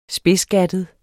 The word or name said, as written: spidsgattet